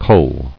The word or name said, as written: Culled